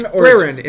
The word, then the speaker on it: Frieren: It's Frieren. It's Frieren. How do you spell it? – Frieren. – Frieren. – F-R-I-E-R-E-N. – And it is a German name, so I think that would be Frieren.